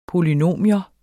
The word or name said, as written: polynomium